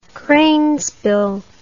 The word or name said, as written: cranesbill